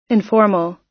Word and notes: informal: American English

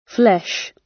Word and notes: flesh: British English

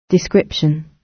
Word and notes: description: British English

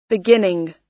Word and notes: beginning: American English

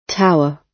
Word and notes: tower: British English

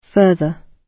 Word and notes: further: British English

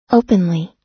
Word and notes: openly: American English

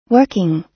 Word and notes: working: American English

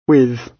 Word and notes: with: British English

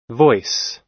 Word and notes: voice: American English

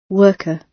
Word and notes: worker: British English

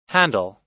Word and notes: handle: American English

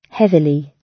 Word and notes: heavily: British English

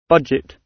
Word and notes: budget: British English

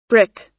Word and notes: brick: American English

